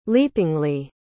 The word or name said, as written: leapingly